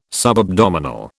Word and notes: subabdominal: British English

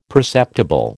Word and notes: perceptible: American English

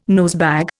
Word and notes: nosebag: Indian

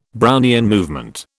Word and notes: Brownian movement: British English

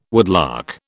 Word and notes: woodlark: British English

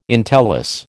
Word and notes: entellus: American English

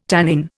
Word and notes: tannin: Indian

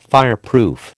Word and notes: fireproof: American English

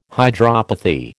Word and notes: hydropathy: American English

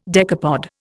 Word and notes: decapod: Indian

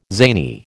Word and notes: zany: American English

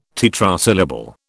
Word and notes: tetrasyllable: British English

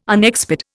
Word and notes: unexpired: Indian

